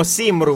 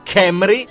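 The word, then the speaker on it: Cymru: Noi diremmo Simru, ma in gallese è Camry,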